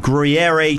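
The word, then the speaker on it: Gruyère: For $30, customers get a 100% North Carolina pasture-raised beef burger topped with Gruyere cheese. Is that how you pronounce that? – Sure, yeah. – Yeah, let's go with that.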